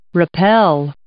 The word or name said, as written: rappel